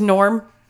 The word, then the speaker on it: Gnorm: So his name is Norm, but it's spelled G-N-O-R-M.